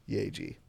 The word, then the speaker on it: Yaeji: That is Y-A-E-J-I, Yeji.